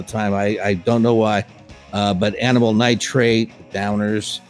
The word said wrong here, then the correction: Drowners: or the Drowners. Did I say that correct? No, not at all. – The Drowners. – Yep. I said it right the second time.